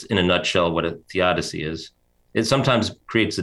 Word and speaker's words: theodicy: Theodicy. – Theodicy, yeah.